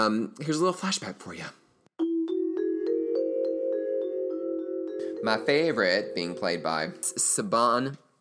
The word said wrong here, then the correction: Siobhan: I knew I was going to screw this up. Okay. Saban. It's Siobhan Fallon Hogan. But in my defense, it is spelled S-I-O-B-H-A-N, which apparently, and I didn't know this, is the way that Irish people spell Siobhan.